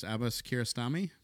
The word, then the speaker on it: Abbas Kiarostami: And And uh, your your uncle, um, hopefully I don't butcher this, Abbas Kiarostami.